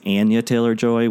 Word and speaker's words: Anya Taylor-Joy: It was good to have it reinforced in me last night that it's Anya Taylor-Joy, which, you know, I had been told.